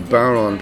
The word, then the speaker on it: bodhrán: No, they only have a fear of bodhrán players. Let me explain what a bodhrán is. It's spelled B-O-D-H-R-I-N, but it's spelled bodhrán.